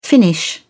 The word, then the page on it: finish up: British English